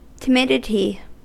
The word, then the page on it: timidity: American English